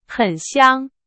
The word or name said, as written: 香